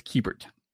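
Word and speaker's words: Keibert: So when he first came up, I thought it was Kiebert, but then there's a key in there. So it's Kiebert. Right.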